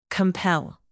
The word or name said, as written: compel